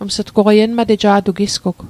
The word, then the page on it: enmateja'toq: Mi'gmaq